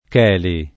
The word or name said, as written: Cheli